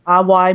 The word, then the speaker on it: Awai: It's Awai Murasaki.